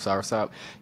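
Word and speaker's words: soursop: So for me, you ever heard sour soap? Sop, if I'm saying that right. – Yes, Sarasop. – Sop, yeah, I said soap. Sour sop.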